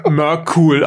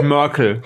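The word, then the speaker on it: Myrkul: ich werde nicht drüber fertig, wie die in Baldur's g 3 Merkul aussprechen. Die sagen nämlich Merkel.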